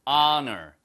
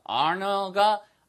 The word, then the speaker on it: honor: honor가 아 발음을 시작하잖아요. 그러니까 what a 아, 너, 이 발음이 힘들어요. 그러니까 모음 앞에, 모음과 유사한 거 앞에 an을 쓴다 이렇게 외우지 마시고요. 발음이 what a an 이렇게 연달아서 모음이 오기가 힘드니까 what an honor 이 발음이 더 쉽거든요.